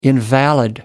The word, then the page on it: invalid: American English